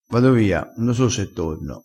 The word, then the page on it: manda panni: dialettale